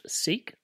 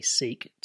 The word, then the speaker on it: Sikh: So the name Sikh, the name of the faith, the religion that you adopt, Sikh, literally seek to seek, but it's spelled S I K H, in our language, in Punjabi, means to learn.